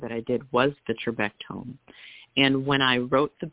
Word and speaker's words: Trabectome: But I wanted to mention The Building Blocks, and I don't know if I'm pronouncing this right, Trabectome Surgery, is that how you say it? – Yes, that's correct.